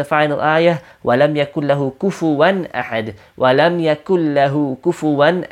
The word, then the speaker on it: كُفُوًا: Notice it's a kaf and not a qaf. A common pronunciation mistake is people say, وَلَمْ يَكُلْ لَهُ with a qaf, وَلَمْ يَكُلْ لَهُ وَلَمْ يَكُلْ لَهُ كُفُوًا أَحَدٍ. It's all kafs over there.